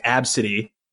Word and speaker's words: Abcde: It's ABCDE. It's spelled out. It's pronounced Absidy.